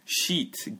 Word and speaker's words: sheet: 네 한 장 두 장 할 때 sheet 길게 해야 돼요.